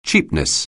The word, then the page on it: cheapness: anglais